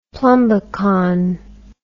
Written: plumbicon

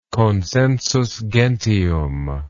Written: consensus gentium

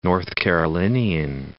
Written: North Carolinian

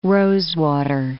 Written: rosewater

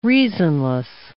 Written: reasonless

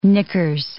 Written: knickers

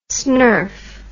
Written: snerf

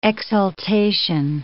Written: exultation

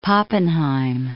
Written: Pappenheim

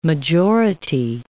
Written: majority

